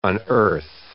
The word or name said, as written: unearth